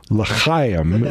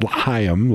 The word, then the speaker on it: L'chaim: They did a wonderful job of bringing that story to life, L'chaim is the word, not L'chaim, L'chaim. Need to have a little speech therapy there on the correct pronunciation.